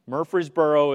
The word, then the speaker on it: Murfreesboro: Murfreesboro is pronounced with two syllables, Murfreesboro.